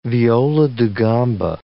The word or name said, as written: viola da gamba